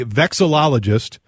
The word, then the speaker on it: vexillologist: He is a vexillologist with the North American Vexillology Association. Am I saying that right, Ted? – You're doing just fine.